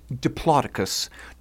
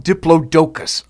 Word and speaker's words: Diplodocus: Like, well, I think the one thing left to say on this is it is pronounced Diplodocus, not Diplodocus.